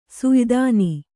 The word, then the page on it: ಸುಯ್ದಾನಿ: suydāni